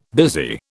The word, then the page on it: busy: British English